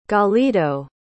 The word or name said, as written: galido